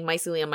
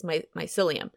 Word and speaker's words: mycelium: And so I was watching this documentary and they were saying that mycelium is apparently how you pronounce it. I've been saying mycelium my whole life and that's not how you say it. It's mycelium.